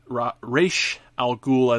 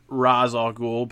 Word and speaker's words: Ra's al Ghul: In this scenario, he goes up against some members of the Light, chiefly Lex Luthor and Ra's al Ghul, as his name is pronounced in this iteration. I'm one of those people. I tend to pronounce it Ra's al Ghul, but I can certainly adapt to Ra's al Ghul.